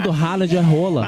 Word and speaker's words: Haaland: É, a gente fala Haaland. Todo mundo na Inglaterra também, o pessoal fala Haaland. – Mas o nome é Rola. – É, na pronúncia norueguesa correta é Rola.